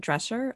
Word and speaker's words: Drescher: It also has Fran Drescher, I believe.